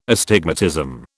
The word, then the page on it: astigmatism: British English